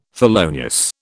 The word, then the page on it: felonious: British English